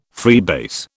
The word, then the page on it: freebase: British English